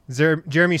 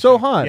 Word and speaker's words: Sochan: And then we have Jeremy Sochan. – Jeremy Sohan. – Sohan. – I think is the correct pronunciation. – Sohan. – Yeah. Well, he's Polish, as we'll get into.